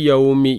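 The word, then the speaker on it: യൗമി (يَوْمِ): അവിടെ ഒന്ന് ശ്രദ്ധിക്കാനുള്ളതേ, ബി യൗമി യാക്ക് ഫത്തഹാണ്. പലപ്പോഴും നമ്മളെ ആൾക്കാർ മാലിക്കി യൗമുദ്ദീൻ, ഫാത്തിഹയിലുള്ള മാലിക്കി യോ യോ നോതും, ഓ യോ യോ എന്നല്ല യാന്നാണ്, യൗമി.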